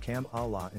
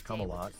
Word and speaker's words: Kamala: The embattled 80-year-old president, who is often mocked for his public gaffes, pronounced the VP's first name as Kamala instead of Kamala. Harris previously explained the pronunciation of her name in a 2016 clip, elaborating, "It's not Kamala. It's not Kumala. It's not Carmela."